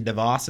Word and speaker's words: DeVos: Um, the I put a note in here though that already today, like the Betsy DeVos, is that how you say her name? – Yeah.